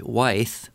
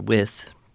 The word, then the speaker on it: Wythe: And he talks a bit about how George Wythe, or With, I've heard it pronounced both ways, as Thomas Jefferson's mentor, helped him learn how to think better about all of these things he was studying.